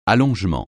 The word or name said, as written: allongement